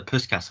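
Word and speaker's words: Puskás: I think the goal is better than the one that he scored against Everton at Anfield, which won the Puskas Award. – Nice. – Is that how you say it or not? – Yeah, no, spot on. Nailed it.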